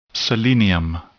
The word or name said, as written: selenium